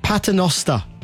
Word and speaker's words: Paternoster: And it's a, I'm sure I'm saying this right, it's a Paternoster elevator. Is that right, Rob? – That's how I understand it.